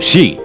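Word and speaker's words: shi (四): Esto se debe a que en la cultura japonesa el número 4 es un número de muy mala suerte, ya que se pronuncia como shi, que en japonés significa muerte.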